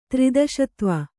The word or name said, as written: ತ್ರಿದಶತ್ವ